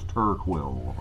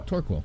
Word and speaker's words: Torquil: I'm not saying his name right. I think it's Torquil, right? – Yeah, I'm horrible with names. – Torquil? – Yeah. – Torquil. – Yeah. – It's like NyQuil.